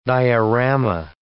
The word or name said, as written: diorama